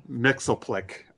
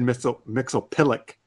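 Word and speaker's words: Mxyzptlk: I always pronounced it Mixoplick. I thought that's how they pronounced it, but they said Mixopilic, or something else.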